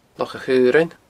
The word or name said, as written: Loch a' Chaorainn